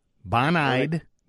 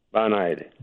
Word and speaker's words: Bonide: Bon-Eyed Mold Max Sonic. – Bon-Eyed Mold Max. – No, not old. That's you and me. Mole, M-O-L-E. – Mole, Sonic? – Yeah, Sonic Spike. Bon-Eyed, B-O-N-I-D-E. – B-O-N-I-E. – B-O-N. – B-O-N.